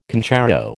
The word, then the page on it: concerto: American English